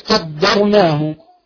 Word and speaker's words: قدرناه: قدرناه هذه الكلمة دائما نسمع من يخطأ فيها كثيرا وينطق الدال ضادا فيقول قدرناه. قدر در ليس الضر لكنها داء. فنقول والقمر قدرناه قد تمام قد وليست قدر ضر مش ضر هي داء.